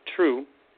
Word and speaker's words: Trieu: Now, Dr. May True, that's spelled T-R-I-E-U, pronounced True, lives at the temple, and she maintains it.